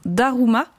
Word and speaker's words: Daruma: Ça s'appelle un Daruma.